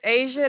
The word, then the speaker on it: Asia: My name is Asia. It is spelled A-S-I-A.